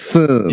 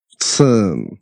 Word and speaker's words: Søren: Okay, because his first name kind of has to get a loogie in your throat to say it correctly. – Søren. – Yeah, something like that. – Søren.